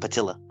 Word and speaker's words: patilla: Patillo? – Patillo. – It sounds like a foot. – Like patilla. – Patillo. That's how it's spelled. Yes. Patillo. – Patilla. Yeah. Feminine.